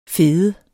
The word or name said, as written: FEDEDE